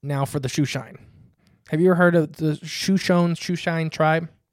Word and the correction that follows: Shoshone: Shoshone, I think. – Is that how you say it? – Yeah, I think it's Shoshone. It's a S-H-O-S-H-O-N-E. – Yeah, Shoshone.